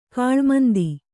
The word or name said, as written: ಗಾಳುಮಂದಿ